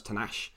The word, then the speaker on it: Tanakh: It's spelled differently, but it's pronounced Tanakh.